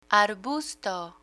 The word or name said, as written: arbusto